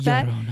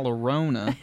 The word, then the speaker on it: Llorona: When you say it like Llorona. That, it sounds like my Sharona. And that's what song plays in my head. My Sharona. – The Curse of La Llorona. – There, thank you.